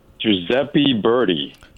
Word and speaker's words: Giuseppe Verdi: Giuseppe Verdi.